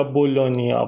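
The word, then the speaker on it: Bologna: این کلمه توی زبان ایتالیایی جی اینه، مثل لازانیا، لازاگنا نوشته میشه، یا بولونیا، بولوگنا.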